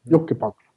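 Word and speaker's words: Jokipakka: Ja, sen Sami Vatanen, Olli Mäti och eh, Jyrki Jokkipakki. Jokkipakki. Jokkipakki. Ja. Inget Päkki där.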